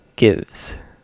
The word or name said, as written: gives